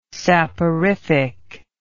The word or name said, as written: saporific